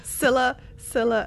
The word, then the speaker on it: Scylla: During the new featured episode, Sia and Charybdis. – Scylla and Charybdis.